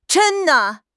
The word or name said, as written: zhen1